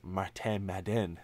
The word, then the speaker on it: Martin Madden: And like you pointed from Montreal, the fact that he speaks French and Pierre Lebrun made a point to point out that it's pronounced Martin Madden on Insider Trading. – Oh, did he? – I never heard it pronounced like that before, which was great.